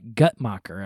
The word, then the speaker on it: Guttmacher: That's according to multiple sources, but predominantly the research being done by a secular research organization known as, and I hope I pronounce this right, Guttmacher, I think is how you pronounce it, G-U-T-T-M-A-C-H-E-R, Guttmacher.